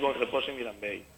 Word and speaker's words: Bonrepòs i Mirambell: El nombre de la población es Bonrepòs i Mirambell. – Lo he pronunciado fatal, ¿verdad? – Sí, Bonrepòs i Mirambell.